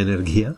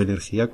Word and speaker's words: Energia: Por último, como nos interesa mucho también el tema de las alternativas, queríamos entrevistar a una persona de Som Energía, se pronuncia Energía, no Energía, como yo pensaba, que es una cooperativa a través de la cual se puede contratar el suministro eléctrico.